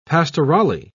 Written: pastorale

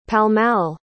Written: pall-mall

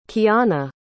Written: kianna

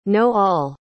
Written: know-all

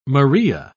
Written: maria

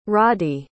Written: roddy

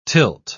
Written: tilt

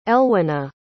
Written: elwyna

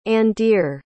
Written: andere

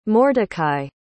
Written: mordecai

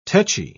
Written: tetchy

